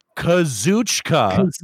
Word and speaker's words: Kazuchika: Say it with me. Kazuchika. – Kazuchika. – Okada. – Okada. – There you go. – Kazuchika Okada.